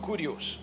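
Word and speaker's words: kurios (κύριος): It's spelled K-Y-R-I-O-S, but it's called kurios, which means sir, master, honor.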